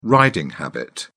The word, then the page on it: riding habit: British English